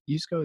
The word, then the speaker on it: Yusko: I love that concept of how powerful the incentives are and the narrative, just like you talked about on Mark, is it Yusko? Yusko, is that how you say it? – Yeah. – On CNBC recently.